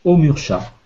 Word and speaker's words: Omursha: Il y a un code qui s'écrit comme ça O-M-U-R-S-H-A. Omurcha.